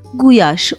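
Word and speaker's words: gulyás: Çünkü aslında kelimede L bile yok. Guyaş olarak okunuyor.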